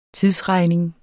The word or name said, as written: TIDSREGNINGS